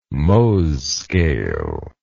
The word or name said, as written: Mohs scale